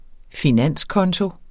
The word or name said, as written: finanskonto